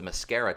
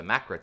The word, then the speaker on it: Macra: This one I always want to pronounce the Mascara Terror, but it's the Macra Terror.